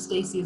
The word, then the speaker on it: Stacy: A lot of times Stacy is spelled with an E.